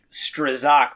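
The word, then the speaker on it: Strzok: I thought it was like Strozak, but it's like stroke. I guess is how it's pronounced.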